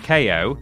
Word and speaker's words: KO: It's pronounced KO, KO because he's got boxing gloves, you see? Like KO.